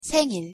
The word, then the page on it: 생일: Korean